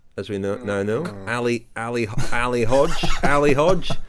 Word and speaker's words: Hodeg: As we speak, the first stage has been run off and won by Álvaro Hodeg. – Álvaro Hodge. – Well, a timely intervention there from the pronunciation police.